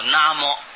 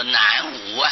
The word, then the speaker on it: 南无: 那么这两个字必定要念作那么，不要念作南无啊，不要照字念，南无就闹出笑话来了。所以一般人念阿弥陀佛，南无阿弥陀佛，哦，南方没有阿弥陀佛，西方才有啊。啊、哦，那么就闹出笑话来。